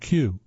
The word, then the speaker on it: queue: The ultimate proof of this might be the word Q, where only the first letter is pronounced. It's spelled, of course, Q-U-E-U-E.